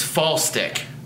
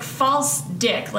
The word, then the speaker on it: Falstich: Sheer, I told you it's Falstick. – That's not how it's spelled. – Well, BJ yelled at us. – So it's spelled Falstich, though. – It's like false dick, like it's like a fake dick, you know, like a sex toy.